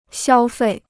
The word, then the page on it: 消费: xiāo fèi